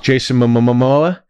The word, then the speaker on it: Jason Momoa: With Jason Momoa.